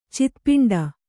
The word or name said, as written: ಚಿತ್ಪಿಂಡ